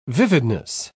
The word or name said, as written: vividness